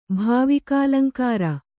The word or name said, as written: ಭಾವಿಕಾಲಂಕಾರ